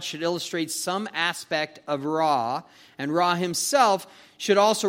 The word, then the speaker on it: Ra: The darkness is also an attack on the sun god Ra. Sometimes they pronounce it Re.